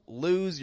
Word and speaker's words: lose: Don't lose your mind. Lose. It's not quick like lose. Lose. But it's lose. It stretches out in the vowel sound. And then it voices that S as a Z.